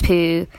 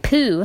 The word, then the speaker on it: Pugh: Oh, how do you say that? Because I say Florence Poe, but then I know some people say Florence Pug. – I say Pugh. And some people say Pug.